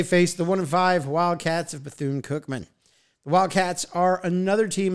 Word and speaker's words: Bethune-Cookman: Southern at Bethune-Cookman.